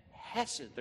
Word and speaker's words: hesed: It is the wonderful word in the Hebrew language, hesed, pronounced with a very hard H that you spit as you say it, hesed.